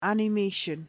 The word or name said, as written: animation